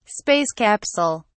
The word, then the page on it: space capsule: British English